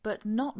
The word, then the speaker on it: but not: One thing to notice, the pronunciation of the but and the not. You don't hear a clear T sound. The T sort of disappears, and that's very typical in English when we're speaking at a native speed and you have a word like but and not together.